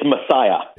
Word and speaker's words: Masaya: It's Masaya. – It is Masaya.